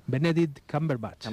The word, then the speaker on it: Benedict Cumberbatch: Benedict Cumberbatch.